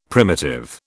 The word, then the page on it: primitive: British English